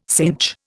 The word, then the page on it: synch: Indian